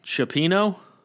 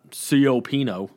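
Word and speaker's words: cioppino: John Baker, Christmas Eve, we always have, I never know how to pronounce this, Chopino? I know it's not Siopino, the way it's spelled.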